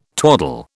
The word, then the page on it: twaddle: British English